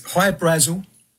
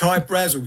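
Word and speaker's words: Hy-Brasil: There's High Brazil, people pronounce it High Brazil, and it's... – Brazil, okay. – Yeah. But it's High Brazil because that's how the Irish would pronounce it, because it's an island that's off the southwest coast of Ireland.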